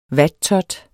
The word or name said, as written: vattot